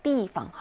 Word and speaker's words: 地方: 그래서 이 한자 그대로 땅, 장소라는 이름으로 띠라고 읽히기도 합니다. 这里是我们休息的地方. 이곳은 우리가 쉬는 장소다. 장소라고 말할 때 띠방 하고 읽죠.